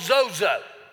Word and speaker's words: sozo: Now, the Greek word for well is a word that's spelled S-O-Z-O, which is called zozo.